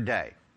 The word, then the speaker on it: dei: He must rise again the third day. He already told you that. And the interesting thing here is that verb must. It's the word, it's transliterated D-E-I, pronounced day.